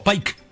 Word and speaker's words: Pike: Y este es de No Te Lo Puedo Creer, una señora que llama Cristina Pique, o Pike. Llamémosle Pico, como nosotros lo pronunciamos.